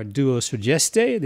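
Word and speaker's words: Duo Sudeste: Robert Thompson and Joey Butler are Duo Sugeste. The name is spelled D-U-O and then S-U-D-E-S-T, which is Portuguese for Southeast.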